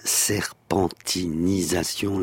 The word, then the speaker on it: serpentinisation: la serpentinisation, l'ai-je bien prononcé là aussi ? – Tout à fait.